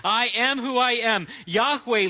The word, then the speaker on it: Yahweh: In the 16th century, somebody thought maybe we pronounce it Jehovah. Same word, but we know that it was probably never pronounced Jehovah. It was something like Yahweh.